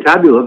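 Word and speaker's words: Tabula: Did I pronounce that properly? – Yeah, we usually say Taboola, but that's fine.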